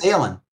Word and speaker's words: Daelin: Daelin. – And so this gal's name is, Lady Priscilla Ashvane. She lost her husband at Theramore at the same time as Lord Admiral Daelin Proudmoore's death.